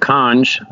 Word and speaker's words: Cange: So I went to a small city called Kange, that's spelled C-A-N-G-E, if anybody's looking that up.